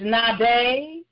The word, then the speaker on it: Nabi: And so the three names for prophet that we're going to see is Nabe, Roe, Jose, and Koze. Amen. And so Nabe is spelled N-A-B-I. Amen. Roe is R-O-E-H.